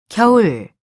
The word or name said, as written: 겨울